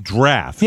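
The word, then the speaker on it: draught: It's spelled drought, but it's pronounced draft.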